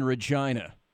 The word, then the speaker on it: Regina: This is like 3 years ago, and I was trying to find you guys Australian beer in Regina. – Oh, yeah, Regina, yeah, I remember. – The city that rhymes with fun.